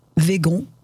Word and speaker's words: vegan: Et que signifie ce nouveau mot, qui est à la fois un substantif et un adjectif, que l'on écrit de différentes façons à l'anglaise? Vegan, V-E-G-A-N, ou à la française, avec un accent aigu sur le E, et que l'on peut prononcer au choix végan ou vegan.